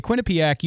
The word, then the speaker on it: Quinnipiac: A Quinny Quinnipiac. Okay, I want to make sure I was saying that correctly. A Quinnipiac University national poll shows that more than 60% of Americans would rather not talk about the subject.